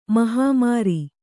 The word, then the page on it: ಮಹಮೇರು: mahamēru